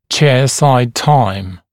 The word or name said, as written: chairside time